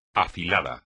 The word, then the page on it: afilado: espagnol